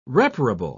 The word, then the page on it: reparable: British English